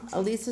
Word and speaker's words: Ilise: Ilise is spelled I L I S E.